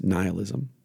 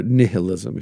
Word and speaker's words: nihilism: And that is the United States of America is dominated by one prevailing ethic, one philosophy, one mindset, and that is nihilism, or nihilism, if you prefer to pronounce it that way.